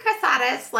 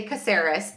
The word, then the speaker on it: Casares: Well, it should be Caceres, like from a background standpoint. But in my husband's family, they say Caceres.